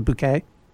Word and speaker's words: Bucket: The woman whose name is Bucket? But she insists that, all right, yeah. And she insists everyone pronounce it Bouquet? – Yes, and right.